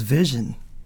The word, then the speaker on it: Vision: It was Vision.